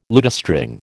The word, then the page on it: lutestring: American English